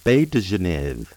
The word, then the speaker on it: Baies de genièvre: Juniper berries in French are pronounced Baies de Genève and spelled Baies, B-A-I-E-S, de, D-E, Genève, G-E-N-I-E-V-R-E.